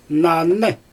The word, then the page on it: nany: Xyzyl